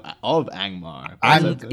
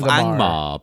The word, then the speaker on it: Angmar: Ang, Well, it was the Witch King of Angmar. – He's of Angmar, but Angmar.